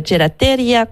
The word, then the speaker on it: gelateria: Et c'est une nouvelle gelateria. Comment on prononce ça? – Gelateria. – Gelateria.